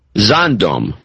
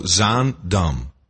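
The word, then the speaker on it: Zaandam: Zondom. – No, Z-A-N-D-A-N. – It's like Amsterdam, so Zondom. – Zondom, okay. – But you have the inflection wrong. It's Zondom, not Zondom.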